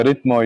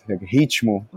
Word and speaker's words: Rytmo: The pronunciation is like this. The Rytmo, it's from Portuguese, Ritmo. – Right. – Ritmo Factory. You can say Ritmo Factory, but the pronunciation here in Czech, it's Rytmo Factory.